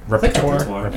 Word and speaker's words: repertoire: Right now, in the current repertoire. Did I say that right? – Repertoire. – Yeah. Whatever. – Yeah. Repertoire. – I Frenched it up a little bit.